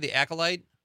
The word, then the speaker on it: The Acolyte: The Acolyte? – Yeah.